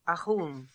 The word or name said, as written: Ahoon